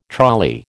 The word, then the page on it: trolley: American English